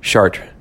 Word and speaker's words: Chartres: No, you can't pronounce the S. Chartres. Chartres.